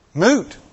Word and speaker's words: moot: What's in my world is actually a very mute. Did I say it right? Moot. Thank you. I will learn that word yet.